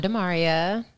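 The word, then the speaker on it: Damaria: I'm Demaria.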